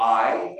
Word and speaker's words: Ai: And there's another story after that, the city of Ai, or Ai, as you would pronounce it,